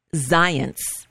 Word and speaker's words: Zients: So the guy who's going to replace him is somebody named Jeffrey Zients. It rhymes with science, but don't let that confuse you.